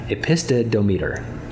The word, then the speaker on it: epistodometer: I usually pronounce it epistodometer, but it's not a word you'll find in your dictionary. It's a word I made up, so okay. – There's no official pronunciation. – Okay. – But I like epistodometer.